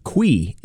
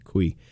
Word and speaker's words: cuy: Qui is the word they use. C-U-Y, qui.